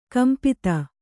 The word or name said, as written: ಕಂಪಿತ